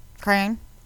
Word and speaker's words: crayon: How do you pronounce C-R-A-Y-O-N? – Crayon. – With one syllable, rhymes with man. Crayon.